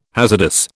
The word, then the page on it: hazardous: British English